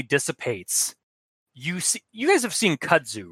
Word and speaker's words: kudzu: It's K-U-D-Z-U. – Kudzu.